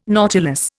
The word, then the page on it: nautilus: Indian